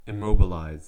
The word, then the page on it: immobilize: American English